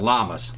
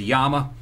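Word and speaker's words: Llama: Or some people call it Llamas. That's what it is. Either the correct pronunciation is a Yama.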